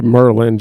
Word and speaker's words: Maryland: Maryland to Merlin, just like they pronounce it over there.